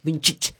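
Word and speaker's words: Vinčić: Me dijeron los eslavos, así se pronuncia. Vincic.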